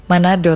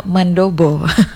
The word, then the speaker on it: Mandobo: Waktu awal-awal saya bacanya bubur Manado, ternyata mandobo.